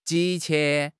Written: 急切

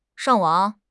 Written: shang4 wang3

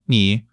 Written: 你

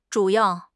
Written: zhu3 yao4

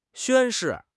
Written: 宣誓

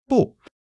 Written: bu4

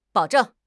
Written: bao3 zheng4

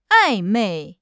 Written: 暧昧

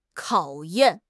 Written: 考验

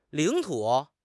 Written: 领土

